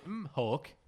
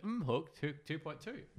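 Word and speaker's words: mHawk: Oh, the Mihawk. The Mihawk. – The M-Hawk. – The M-Hawk. – The M-Hawk 2.2.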